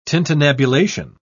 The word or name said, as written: tintinnabulation